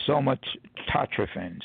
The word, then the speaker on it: somatotrophin: Human growth hormone is also, and this may be a hard You may have seen this pronunciation before. It's somatotrophin.